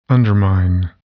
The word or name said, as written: undermine